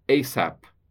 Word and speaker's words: ASAP: ASAP. Assim como você diz NASA. É um acrônimo porque ele é pronunciado como uma palavra, apesar de abreviar uma expressão.